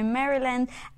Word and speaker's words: Maryland: All right, we've got some fan shout-outs for our viewers from Texas, Missouri, Alabama, Florida, Kansas City, Colorado, Maryland, and Arizona. Did I pronounce Maryland right? – You did. Yeah, I was totally with you.